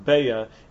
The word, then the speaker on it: beitza: they don't pronounce the word beitza, that they call it beya in the Aramaic form.